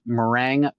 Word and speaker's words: Meringue: So it is the, I'm probably going to mispronounce this, but I hope I don't, Meringue Pokemon. – Oh, no, yeah. You nailed it.